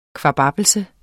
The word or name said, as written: kvababbelse